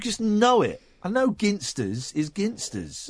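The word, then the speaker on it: Ginsters: Yeah, it's a hard G. It's Ginster's.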